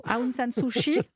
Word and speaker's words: Aung San Suu Kyi: D'abord, est-ce qu'on pourrait se mettre tous d'accord, si vous le voulez bien, sur la prononciation Aung San Suu Kyi, c'est ça ? – Voilà.